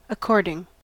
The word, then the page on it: according: American English